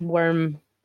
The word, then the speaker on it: wyrm: It's worm as in worm with a Y, so W-Y-R-M, yeah.